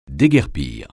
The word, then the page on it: déguerpir: français